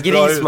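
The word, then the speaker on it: Griezmann: ja men jag gillar Grisman, det är Grisman, bra uttal.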